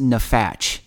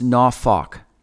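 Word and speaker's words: nafok (נפח): And it looks in English like it should be pronounced nafach, but it's actually pronounced nafok.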